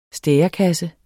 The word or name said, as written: stærekasse